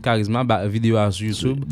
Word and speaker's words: Karizma: Le groupe, c'est Charisma, comme ça se prononce en créole. Charisma, K-A-R-I-Z-M-A. Donc, Charisma.